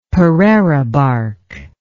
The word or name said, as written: pereira bark